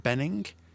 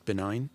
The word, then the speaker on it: Bening: Annette Bening, not pronounced benign.